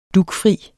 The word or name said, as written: dugfri